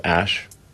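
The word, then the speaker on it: Æ: The AE is like... pronounced Ash.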